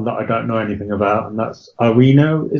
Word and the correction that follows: Awoniyi: is that how you pronounce it? – I think it's Awoniyi, yeah, but anyway. – Awoniyi, yeah.